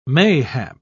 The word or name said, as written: mayhap